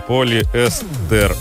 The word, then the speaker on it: полиэстер: Полиэстер на «э», на букву «э». Так как слово пришло из английского языка, где ударение падает на гласную в корне. Вариант с ударением на последний слог считается ошибочным, хотя иногда встречается разговор вещи.